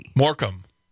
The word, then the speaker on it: Morecambe: Morecambe. It's pronounced Morecambe.